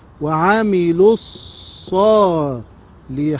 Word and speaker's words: وعملوا الصالحات: طيب مثلا كده، وعملوا الصالحات، وعملوا، وعملوا، طبعا ألف صفر مستدير لا تنطق، وعملوا الصالحات، شوفها يا حبيبي كده معايا، وعملوا الصالحات.